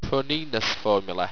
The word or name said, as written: pronenous